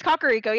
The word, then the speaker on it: Kakariko: He's like, Kakariko Village, and I would always said Kakariko. – It's Kakariko, yeah. I knew that one. – Which makes sense, because if in Japanese, it would be pronounced Kakariko.